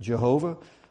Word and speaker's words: Jehovah: Some pronounce it Yahweh, some pronounce it Jehovah. Jehovah, the pronunciation Jehovah really came as a result of a clerical error, a scribe's error. Don't tell the Jehovah's Witnesses that, but probably most likely is pronounced Yahweh.